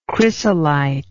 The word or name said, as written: chrysolitic